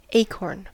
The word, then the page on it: acorn: American English